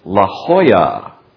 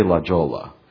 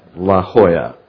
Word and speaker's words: La Jolla: And then Kenneth Trax, who's from, can you say it right? It's La Jolla. Yes, don't say La Jolla. La Jolla.